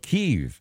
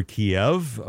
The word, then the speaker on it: Kyiv: I read you that 900 people near the capital of Kyiv, or Kiev, depending on which pronunciation you want to use, in Ukraine, 900 people apparently executed by gunshot.